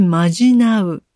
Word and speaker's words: 呪う: おまじないは、呪うと書いてまじなうと読むようで、まじなうと念ずるは、ややニュアンスが違う感覚のような気もしますが、近いところにいることは間違いなさそう。